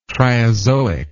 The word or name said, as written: triazoic